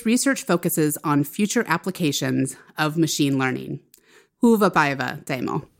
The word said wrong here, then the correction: hyvä päivä: Hyvä päivä.